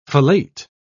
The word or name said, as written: fellate